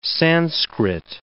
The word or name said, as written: Sanskrit